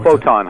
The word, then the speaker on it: Photon: How did we pronounce that last time? I said it wrong before. – Photon, Hydro Photon. – It is Photon. Yep. It is. Okay. A little bit of that French twang to it.